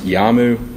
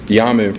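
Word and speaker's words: Iyamu: And I believe it's pronounced YAMU. It's I-Y-A-M-U, which is 2006 Merit System Protection Board, Lexus 710.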